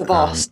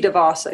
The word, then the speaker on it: DeVos: And a very interesting subject right now, and two controversial figures in our president and Betsy Davos, or Davos, however you say her name. – DeVos. – You know, I know that you guys were the, what is it? DeVos? – Betsy DeVos, I think. – Oh, that's DeVos.